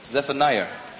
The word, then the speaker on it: Zephaniah: Zephaniah. I knew I was Zephaniah. Saying it wrong.